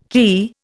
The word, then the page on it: quay: Indian